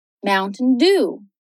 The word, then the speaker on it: Mountain Dew: Já a sílaba lá do final soa como um O longo, do, do. Mountain Dew.